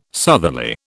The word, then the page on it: southerly: British English